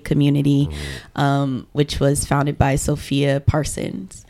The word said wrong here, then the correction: Parsa: Parsa. Parsa. mm Parsa. I always get her name wrong.